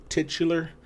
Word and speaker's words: titular: How do you say that word? Titular? – Yes.